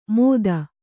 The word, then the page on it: ಮೊತ್ತ: Kannada